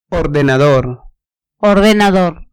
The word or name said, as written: ordenador